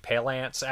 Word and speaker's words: Palance: I always say Jack Palance and then I try to pronounce it correctly. I'm like, Palance.